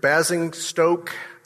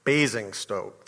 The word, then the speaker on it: Basingstoke: Daniel Brandon, 31, and I sent a similar message out last night, died from asphyxiation at his home near Basingstoke, Hampshire. Did I say that right? I did not say that right. Basingstoke, thank you, Barb.